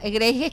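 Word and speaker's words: Grease: Grace es que se pronuncia de grasa.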